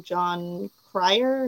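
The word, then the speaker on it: Jon Cryer: John Cryer, am I saying that right? – Yeah.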